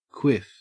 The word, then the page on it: quiff: Australian English